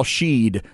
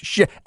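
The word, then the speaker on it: Shead: Let's pronounce his name correctly. I know it's Sheed, but don't be lazy. Ask the young man. Ask Kelvin Sampson. It is pronounced Shed.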